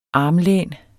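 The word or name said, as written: ARMLÆNET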